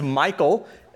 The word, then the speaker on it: Michal: Her name is Michael. It's spelled M-I-C-H-A-L, like David's wife.